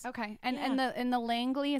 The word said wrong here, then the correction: Laneige: is that how you say it? – Laneige.